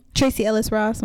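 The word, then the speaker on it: Tracee Ellis Ross: So I'll notice, I'm like, oh, wow, Tracy Ellis. Is that how I say her name? – Tracy Ellis Ross.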